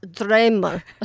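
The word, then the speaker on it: Draumur: I can't pronounce it with the right Icelandic accent, but yes, Dramer. So Dramer, which means dream in Icelandic.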